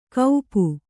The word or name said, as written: ಕೌಪು